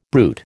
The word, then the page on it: brute: American English